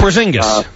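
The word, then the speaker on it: Porziņģis: Porzingis.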